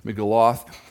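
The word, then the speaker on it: Megillot: The Jewish festivals have a thing called Megillah, or Megaloth, if it's pronounced correctly, Megaloth.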